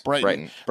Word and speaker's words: Brighton: Let's head over to Southeast England to the seaside resort of Brighton. I think that's how you pronounce it. Britain, yeah, Brighton. – Where Brighton.